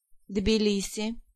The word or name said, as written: Tblisi